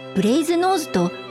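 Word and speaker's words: Brasenose: ブレイズノーズカレッジがなぜそう呼ばれるのかとか、どう発音するのかと迷う人が多くいますが、読んで字のごとくブレイズノーズと発音されます。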